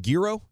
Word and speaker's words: gyro: How do you say the word? Is it gyro? G-Y-R-O? – Gyro. Gyro, yeah.